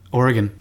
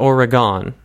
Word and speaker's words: Oregon: How do you pronounce it? Oregon. Not Oregon. I don't think so. It's not a geometric shape.